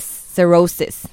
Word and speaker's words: cirrhosis: cirrhosis. Cirrhosis.